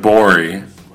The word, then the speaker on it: Gborie: If you want to hear more from David Borie, please go Google his name. David G-B-O-R-I-E. The G is silent on Twitter.